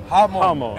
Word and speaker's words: Hamon: Moan. Like ha and moan. – Hamon.